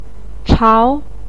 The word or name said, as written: chao2